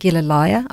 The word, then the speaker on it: Gilleleje: It's not an easy name to pronounce, but it's in English you pronounce it Gilleleje, I believe.